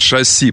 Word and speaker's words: шасси: Шасси, правильно, на «и». Но, знаете, это проблема. Это действительно французское слово. Просто у меня так же, как компас-компас, летческое ударение иногда проскакивает.